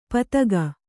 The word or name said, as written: ಪತಗ